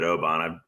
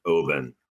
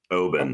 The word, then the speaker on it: Oban: And it's interesting too, because I've always called it Oban. I've been drinking this probably for 25 years, but my Scots friends tell me that it's actually pronounced Oban. So Oban.